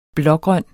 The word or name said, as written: blågrøn